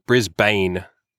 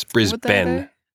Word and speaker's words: Brisbane: But non-Australians would pronounce it Brisbane, but it's Brisbane.